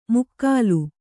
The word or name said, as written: ಮುಕ್ಕಾಲು